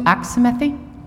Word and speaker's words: Axamethy: Sue Axmathy. – Can you spell that last name? – Sure. A-X-A-M-E-T-H-Y.